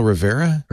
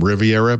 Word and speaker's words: Rivera: Raphael Rivera? – Yes. – Double R. – It's just that pronounce his last name Riviera, but he doesn't like that.